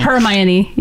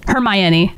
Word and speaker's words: Hermione: Hermione. – Hermione, yeah. – Say it again. – Hermione.